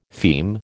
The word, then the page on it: feme: American English